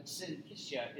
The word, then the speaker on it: syncytia: But syncytia is how that's pronounced.